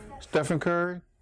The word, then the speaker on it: Stephen Curry: Am I saying his name right? – Stephen Curry.